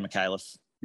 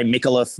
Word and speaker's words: McAuliffe: How do you pronounce his surname again? McAuliffe, is it? – Jared McAuliffe. – McAuliffe. – Yep. You get a few different, they'll say Mikuliff, Mikhailov.